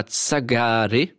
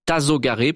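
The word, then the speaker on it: Tasogare: Tsagare. Je pense que ça se prononce pas comme ça. Tazogare plutôt.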